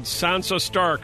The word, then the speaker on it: Sansa Stark: Well, Turner, who played Sansa Stark.